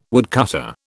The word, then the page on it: woodcutter: British English